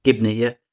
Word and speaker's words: Gibney: Is that how you say it, Gibney? Mm-hmm. You say that? Yeah? – Gibney, yeah.